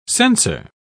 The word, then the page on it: censer: British English